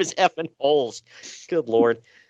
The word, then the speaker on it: Rolls: Is it not Rolls? – R is never pronounced, but the R always has an H. – Right. So that's what, is it Holes? – I think it's Holes.